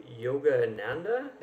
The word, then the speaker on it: Yogananda: She started off with as an associate producer on a award-winning documentary, Awake, the Life of Yogananda.